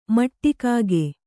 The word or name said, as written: ಮಟ್ಟಿಕಾಗೆ